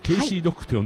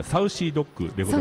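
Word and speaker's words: Saucy Dog: ちょっと先ほどね、あの石原さんの所属バンドケイシードッグと呼んだんですけど、はい、確かサウシードッグでございます。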